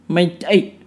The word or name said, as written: maita'i